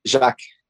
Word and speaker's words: Jacques: Jacques.